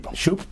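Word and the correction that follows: Schoep: I'm sorry, Scoop. He pronounces it Scoop. – Scoop.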